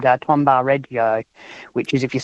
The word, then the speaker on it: Tombaugh: And Tombaugh is the pronunciation that I usually say. – Tombaugh. You know, I did wonder if it would be bar or bow at that rate.